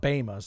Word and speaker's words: Bema: It's pronounced Bema, so call someone out.